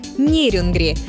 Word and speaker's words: Нерюнгри: Однако в настоящее время предпочтительно ударение на первый слог – Нерюнгри. Хотя некоторые словари все еще дают вариант с ударением на последний слог.